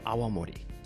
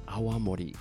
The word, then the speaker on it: Awamori: Ryukyu Awamori. Awamori. A-W-A-M-O-R-I. You have to be careful because if you don't pronounce it carefully, people will think you're talking about Aomori Prefecture.